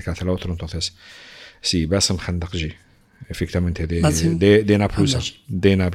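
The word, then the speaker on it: Bassem Khandakji: El escritor Bassi Kandahi. ¿Cómo se dice? ¿Tú lo conoces? – Repite el nombre, por favor. – Bassi Kandakji. – Bassem Kandakji.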